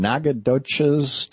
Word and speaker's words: Nacogdoches: And welcome back, Joe, in Nacogdoches, Texas.